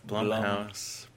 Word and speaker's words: Blumhouse: We will be talking about the production company of Blumhouse. Not Blumhouse. Blumhouse Productions. Rhymes with plum. Rhymes with dumb. And rhymes with Wow.